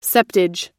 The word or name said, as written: septage